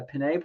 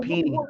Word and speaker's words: penne: I believe it's a penne pasta. Did I say that right? – I'm not sure how you pronounce that, but I know, yeah, it's not peony. I don't think people eat peony pasta.